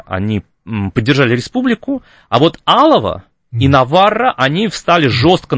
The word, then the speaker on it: Алава: И за это Наварра и Алава при франкизме, да, мы всегда говорим про то, что франкизм это такой унифицирующий. – Да-да-да. – Но Алава, прошу прощения, там ударение на первую букву,